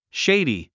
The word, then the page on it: shady: ʃéidi; シェイディ